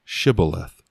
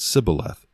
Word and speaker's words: Shibboleth: If he said, No, then they would say to him, Say now, Shibboleth. But he said, Sibboleth, for he could not pronounce it correctly.